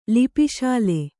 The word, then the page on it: ಲಿಪಿಶಾಲೆ: Kannada